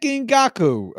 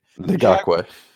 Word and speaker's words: Ngakoue: Defensive end Yannick Ngakoue. I did not say that right. – Ngakoue. – Ngakoue. Damn, I was so close to the start of it.